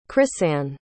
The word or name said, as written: crisann